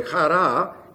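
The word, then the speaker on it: Chara (χαρά): Chara is H-A-R-A, simply. The H is actually a guttural, very slight guttural. Chara, do you hear it? It's not as strong as the Hebrew guttural. It's not way deep down like German or Hebrew. It's real light. Chara. It's there. You can hear it.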